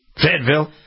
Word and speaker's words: Fayetteville: Wilmington, Fayetteville, is that how you pronounce it? Fayetteville? – Yep, that sounds Fayetteville.